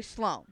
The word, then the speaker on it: Sloan: Schlong? – Sloan. – Schlow. – Slow. – Schlow. – There you go. – S-C-H-L-O. – S-L-O-A-N. But you got to pronounce Sloan. – It differently. Yeah. – Sloan. – Yeah. – I thought you said slow.